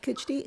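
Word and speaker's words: Khichdi: And is that when you created midlife, correct me if I'm saying this wrong, Kichdi? – Yes. – Okay, is it midlife Kichdi? – Yes.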